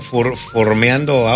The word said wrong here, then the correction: farmeando: Ah, estás formeando auras. Farmeando, como se diga. – Ah, farmeando, tienes razón, farmeando.